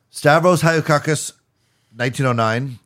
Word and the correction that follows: Halkias: Say his name properly. – Haikias. – Keep going. – Halkias. I think that sounds about right.